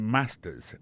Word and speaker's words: master's: Accent tonique là, I got a master's. Master's là, c'est mot créé, les maîtrises là. Regardez bien pour voir que l'accent tombé sur le premier syllabe là.